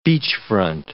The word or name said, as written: beachfront